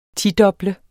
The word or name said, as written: tidoble